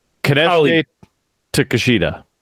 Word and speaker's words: Konosuke Takeshita: Konosuke Takeshita. I know I have Takeshita right. – Oh. Konosuke Takeshita.